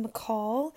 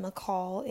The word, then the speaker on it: Michal: But it's a Hebrew name, and it's David's first wife in the Bible, so it's pronounced Michael if you are Jewish, and then McCall is like the more English translation, and then the super, super, super bare bone translation is Michael.